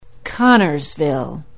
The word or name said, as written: Connorsville